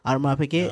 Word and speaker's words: Audemars Piguet: Audemars Piguet. No. Yes. Audemars Piguet is how you pronounce it.